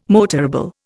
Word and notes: motorable: Indian

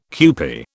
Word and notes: kewpie: British English

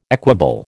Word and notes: equable: American English

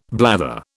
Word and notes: blather: British English

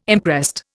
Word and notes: imprest: Indian

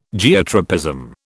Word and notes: Geotropism: British English

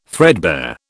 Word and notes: threadbare: British English

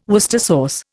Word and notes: Worcester sauce: Indian

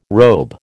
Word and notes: robe: American English

